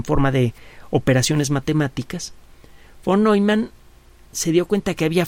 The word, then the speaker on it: Neumann: Se escribe Neumann, la pronunciación es Neumann.